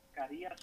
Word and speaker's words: Carias: My name is Minor Carillas. I don't have with me. I'm sorry, I'm driving. – Last name? Spell it. – Carillas, C-A-R-I-A-S.